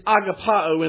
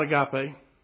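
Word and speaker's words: agapao (ἀγαπάω): And by the way, for all the agape fellowships all across America, it's agapao in the Greek, not agape.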